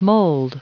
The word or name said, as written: mold